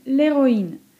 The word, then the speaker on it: l'héroïne: Wyjątkiem od zasady, że h pozostaje przedechowe w obrębie wyrazów należących do jednej rodziny, jest para le héros i l'héroïne, gdzie w rodzaju męskim le héros mamy h aspiré, a w rodzaju żeńskim l'héroïne mamy h muet.